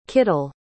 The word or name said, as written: kittel